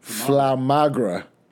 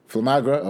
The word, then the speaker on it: Flamagra: Flamagra. – Not Flamagra. – Flamagra, okay.